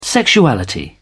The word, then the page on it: sexuality: British English